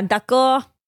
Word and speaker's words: d'accord: D'accord. – D'accord. Am I saying it right? – Yep.